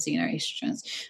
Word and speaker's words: xenoestrogens: So there are fake hormones in the environment known as xenoestrogens, and I think in America they pronounce it xenoestrogens.